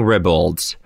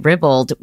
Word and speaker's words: ribald: Hey, but I think the second definition is with a long I. – Hmm. – So I think either's acceptable. So I'd like to apologize, Bowen, for correcting ribald, which sounds like dribble. That's why ribald sounds a little better to me.